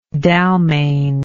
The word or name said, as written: Dalmane